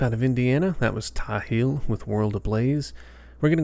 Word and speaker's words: Ptahil: It's pretty cool, but anyways, I think the P is silent at the beginning of this band name. It's Ptahil, and I'm pretty sure they pronounced it Ptahil. So that's how I'm going to pronounce it.